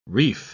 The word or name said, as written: reef